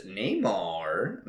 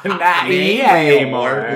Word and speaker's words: Namor: In other Marvel news, Neymar. Maybe. Did I say that right? – No.